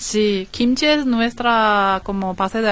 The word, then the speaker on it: kimchi: ¿Sí, kimchi. ¿Lo pronuncio bien? Ajá, sí, dice bien.